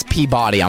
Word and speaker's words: Peabody: No, that's Peabody.